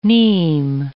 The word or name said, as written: neem